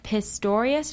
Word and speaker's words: Pistorius: Oscar Pistorius, did I say that right? – Yeah.